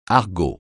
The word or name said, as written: argot